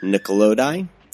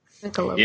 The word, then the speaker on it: Nicolodi: Now, this was starring Jennifer Connelly and Donald Pleasance, as well as Daria Nicolodi. Did I pronounce that correctly? – Nickelodeon. Yeah.